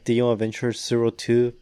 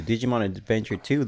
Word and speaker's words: Digimon Adventure 02: And yeah, it's still pronounced like Digimon Adventure 02, but I mean, who wants to say the zero, you know? – Yeah, so we just say two. Digimon Adventure 2, The Beginning.